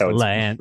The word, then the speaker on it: La Haine: It's La Haine. It's La Haine, I guess, in French.